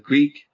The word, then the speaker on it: Greak: I've been playing a Metroidvania called Greek, but it's spelled G-R-E-A-K.